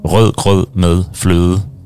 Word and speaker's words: rød grød med fløde: The test, usually if you want to test people's pronunciation in Danish, you have to say the name of a dessert called rød grød med fløde, because it's all these ø and d sounds, there's five of them in one small, yeah.